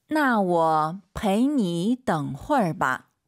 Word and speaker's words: 我陪你等会儿吧: 나我陪你等会儿吧. – 我陪 삼성이성 아아 我가 빠르게 지나가는 게 포인트예요. 나我陪你 아이고 또 삼성 삼성 중간에 숨어 있죠. 陪你 해도 알아듣겠지만 陪你等会儿吧 이렇게 발음하세요.